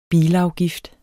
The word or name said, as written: bilafgift